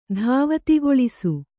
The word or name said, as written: ಧಾವತಿಗೊಳಿಸು